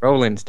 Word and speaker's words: Rollins: It's spelled R-O, but you pronounce it R-A. His name is Rollins, damn it.